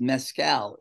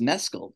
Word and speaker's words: Mescal: And by the way, it is not pronounced Mescal. It is pronounced Mescal because I saw him on Jimmy Kimmel and he said everybody's been mispronouncing my name.